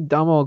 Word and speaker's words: Domhnall: The only Domhnall Gleeson movie that I've seen, I hope I'm pronouncing that correctly. There's like random H in the middle of nowhere.